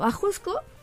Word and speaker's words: Ajusco: Ajusco se pronuncia también, o se pronunciaría, a lo mejor estoy mal, pero más o menos como Ajochco.